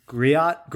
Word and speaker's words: griot: It's like Griot, G R I O T Griot. Griot is so. is I think right.